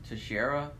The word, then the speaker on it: Teixeira: I remember hearing about that, and I think the last name is technically pronounced Teixeira. – Okay. – Yeah, Teixeira thing's a little silly. It's like Teixeira, because there's like a fighter named Glover Teixeira, so I remember hearing about this.